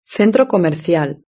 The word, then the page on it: el centro comercial: European Spanish